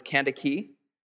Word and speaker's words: Kandake: Verse 27, Philip rose and went, and there was an Ethiopian, a eunuch, a court official of Candace. You could also pronounce this Kandake.